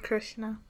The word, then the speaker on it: Krishna: Krishna.